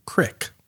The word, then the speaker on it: Creek: This is the Creek, C-R-E-E-K, which is, I think, a play on Creek, like K-R-I-E-K, which is a sour style beer. This is a Creek, the golden sour beer aged in oak barrels with... Balaton cherries and Montmorency cherries, probably. – I believe the correct pronunciation of that word is crick.